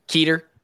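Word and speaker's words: Kueter: Is it Cutter, Cuter, Cooter? – I believe the Discord said it's Keeter. – Keeter. Ben Keeter.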